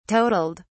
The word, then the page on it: totaled: British English